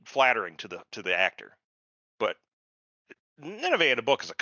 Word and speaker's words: Nineveh: So this, yeah, Wheel of Time, you know, so again, the casting, Nineveh, it's Nineveh, by the way. It's, if you look it up, that's how it's pronounced. – Oh my God, yeah. – Not Nineveh.